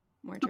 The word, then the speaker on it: Mordred: Mordred? Is that how you would say his name? – That's yeah, how I say it. – Mordred, okay, yeah.